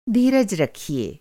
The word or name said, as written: धीरज